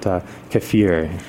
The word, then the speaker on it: kefir: Anyway, you seem very attached to kefir. Is that how you say it? – I call it kefir. – Kefir. – We okay. – call it kefir up in Canada.